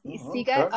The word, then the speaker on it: Sziget: It's called Sziget.